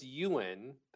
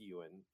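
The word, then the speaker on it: Eoghan: Dear Mr. Holmes, my name is Ewan. That's how you pronounce that, by the way. – Oh, did you look that up or you knew it already? – I want to be smart and tell you I knew it already. – Okay. – But I did look it up. – All right, cool. – It's Gaelic. It's E-O-G-H-A-N. It's pronounced Ewan.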